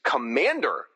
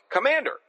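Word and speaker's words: Komand'r: I always like that her name is Commander, like Commander, given her militaristic attempts to take over anything. You know, Ange, I don't know that I ever pronounced her name out loud before and never realized it's like Commander.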